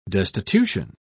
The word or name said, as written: destitution